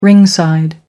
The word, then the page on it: ringside: American English